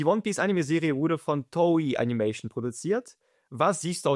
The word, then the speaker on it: Toei: Vielleicht heißt es ja Toei Animation. – Es ist Toei.